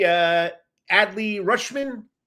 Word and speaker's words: Adley Rutschman: Andy Adley Rutschman.